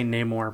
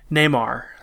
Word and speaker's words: Neymar: So a lot of people are making fun of, I want to say Namor, but that's not how you say his name. – I was about to say, that's a Marvel character. – It's in any Neymar, that's his name. N-E-Y-M-A-R.